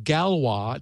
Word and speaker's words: Galois: and that is this so-called Galois. It's, it's spelled G-A-L-O-I-S, and Galois fields are a are, are a well-known uh, component of crypto.